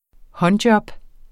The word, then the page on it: håndjob: [ ˈhʌnˌdjʌb ]